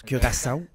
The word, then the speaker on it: Curaçao: Parce que c'est comme ça que ça se prononce, Curaçao.